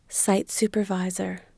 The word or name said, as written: site supervisor